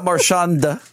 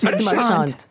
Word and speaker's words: Marchand: It's not Marchand, right? – It's Marchand. – Marchand. – I said Marchand.